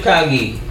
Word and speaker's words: Kage: Who, who is the Kage? The Kages. There's five Kages. So it's it's Kanye with a G. So Kage. Kage.